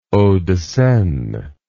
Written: hauts de seine

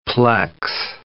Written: plex

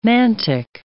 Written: mantic

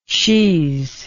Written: she's

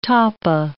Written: tapa

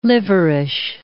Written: liverish

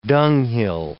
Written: dunghill

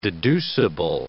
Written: deducible

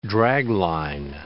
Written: dragline

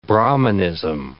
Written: Brahmanism

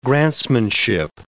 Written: grantsmanship

